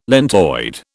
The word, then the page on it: lentoid: British English